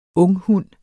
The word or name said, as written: unghund